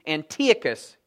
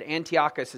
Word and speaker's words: Antiochus: This little horn in chapter 8 we're going to be introduced to is a guy by the name of Antiochus Epiphanes, or maybe Antiochus, you might have heard him be named. But Antiochus is actually the correct pronunciation.